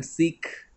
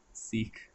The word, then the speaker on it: Sikh: See, you anglicized your pronunciation also. I'm not saying this because I'm a Sikh. Sikh. – I don't know why I've done that, but I like saying Sikh instead of Sikh.